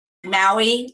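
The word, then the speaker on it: Maue: It is spelled M like Mary, A like apple, U like umbrella, and E like eagle. Maui like the Hawaiian island.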